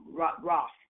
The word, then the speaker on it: Ra'af: You can see it, Riff, Raf, but it's pronounced Raf.